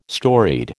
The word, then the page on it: storied: American English